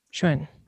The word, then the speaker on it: Xuan: It's like Xuan. – Xuan. – Okay. It's almost like Shen, but there's almost a U.